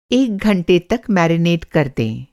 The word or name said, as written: to marinate